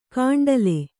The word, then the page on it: ಕಾಂಡಲೆ: Kannada